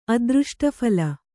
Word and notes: ಅದೃಷ್ಟಫಲ: adřṣṭaphala

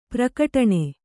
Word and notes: ಪ್ರಘಟ್ಟನ: praghaṭṭana

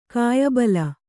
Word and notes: ಕಾಯಬಲ: kāyabala

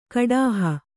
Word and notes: ಕುಡುಹಿ: kuḍuhi

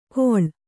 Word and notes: ಕೊಳ್: koḷ

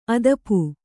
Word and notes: ಆತಪ: Kannada

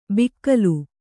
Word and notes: ಬಿಕ್ಕಲು: bikkalu

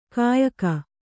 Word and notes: ಕೊಯ್ಕೆ: koyke

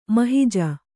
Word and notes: ಮಹೀಜ: mahīja

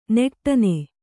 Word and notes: ನೆಟ್ಟನೆ: neṭṭane; Kannada

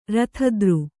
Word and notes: ರಥದ್ರು: Kannada

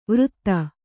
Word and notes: ವೃತ್ತ: vřtta

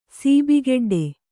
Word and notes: ಸೀಬಿಗೆಡ್ಡೆ: kannada

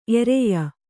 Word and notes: ಎರೆಯ: ereya

